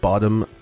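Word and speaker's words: Bodom: And welcome to this true crime episode all about the Lake Bottom murders. That's B-O-D-O-M, not B-O-T-T-O-M. – But we will be pronouncing it like Bottom.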